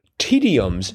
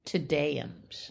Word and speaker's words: Te Deums: And speaking of Tums, the one right next to it, eight down, hymns of Thanksgiving, tediums. – No. Tedeums. – Oh, thank you. Because it's spelled T-E-D-E-U-M-S. And so I looked at it as tediums. – No. Tedeums. – Tedeums. It sounds much better and less tedious.